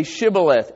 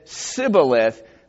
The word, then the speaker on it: shibboleth: The difference is shibboleth, and it says here in verse 8, if they say nay, they say unto him, say shibboleth. And he said sibboleth, because he could not frame to pronounce it right.